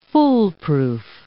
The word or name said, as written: foolproof